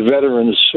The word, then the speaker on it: veteran: Word veteran, it's often pronounced veteran makes me crazy. Intelligent people and radio and TV anchors, it's veteran, not veteran. Even guys that are in the military or promoting any veterans organizations who should know better. It is a three-syllable word, veteran.